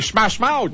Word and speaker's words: Smash Mouth: Un jour, je vais le prononcer comme il faut le nom de ce groupe. C'est Smash Mouth. – Oui, t'es bon.